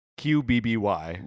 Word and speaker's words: Qbby: It has only one vowel, arguably, in it at the end, but it's definitely a two-syllable word. QBBY.